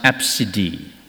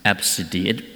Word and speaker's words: ABCDE: So it's actually pronounced Absidy. So if you are running out of ideas and you're going to have a son or even a daughter, right, you can give that name to him or her, ABCDE, and it's just pronounced Absidy.